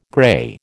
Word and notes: grey: American English

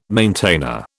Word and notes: maintainer: British English

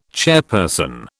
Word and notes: chairperson: British English

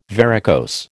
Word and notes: varicose: American English

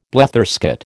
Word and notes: bletherskate: American English

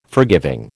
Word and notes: forgiving: American English

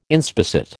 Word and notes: inspissate: American English

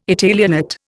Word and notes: Italianate: Indian

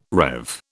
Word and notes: rev: British English

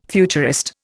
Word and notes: futurist: Indian

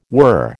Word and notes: were: American English

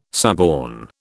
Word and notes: suborn: British English

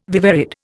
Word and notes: viverrid: Indian

